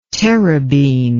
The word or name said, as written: terebene